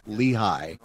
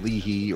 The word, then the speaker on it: Lehi: you're inevitably going to mispronounce some of those communities, and it'll become Leahy or Moroni or some unfortunate, and that can wreck a book for me as someone who knows the real pronunciations of those place names.